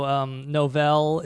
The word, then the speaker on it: Novell: And you know, so Novell is among the various companies that accuses them of... – That's pronounced novel.